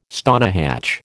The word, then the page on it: stonehatch: American English